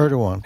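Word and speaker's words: Erdoğan: Well, Erdogan, is that how you pronounce it? – Erdogan.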